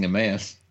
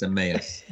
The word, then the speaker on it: Emmaus: Where you're deliberately mispronouncing Emmaus. – Emmaus. – Which you know, it may well be pronounced a mouse in real life, but good Catholic Irish boys know it's Emmaus.